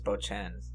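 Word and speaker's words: Beauchesne: B-E-A-U-C-H-E-S-N-E. I don't even know where to start with those syllables, or those vowels. – I think it's Bochen.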